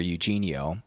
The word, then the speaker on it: Eugenio: And Eugenio, E-U-G-E-N-I-O. If you were just speaking in old-timey, good old-fashioned American English, it'd be Eugenio, but or Eugenio. But no, this is Eugenio Suarez.